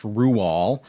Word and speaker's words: Roald: And don't write into me, his name is pronounced Roald.